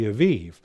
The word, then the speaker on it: Aviv: As we're going to see, he refers to it as the month of the Aviv, you know, or oftentimes in our English translations, it'll have A-B-I-B, you know, but phonetically in Hebrew, it's more A-V-I-V is how it would be correctly pronounced.